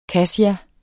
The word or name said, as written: kassia